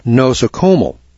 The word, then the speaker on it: nosocomial: That's spelled N-O-S-O-C-O-M-I-A-L. Nosocomial, I guess, is the way we'd pronounce that.